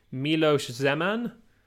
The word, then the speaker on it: Miloš Zeman: Anyway, the runoff election will be happening on the 26th between incumbent president Miloš Zeman, mm-hmm. I think I'm pronouncing that right, yep.